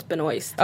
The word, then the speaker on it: Benoist: Did I say that right? Benoist? Benoist? – I want to say it's Benoist.